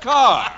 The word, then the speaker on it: Kerr: And I include Douglas Fairbanks Jr. and Deborah Kerr, pronounced Carr.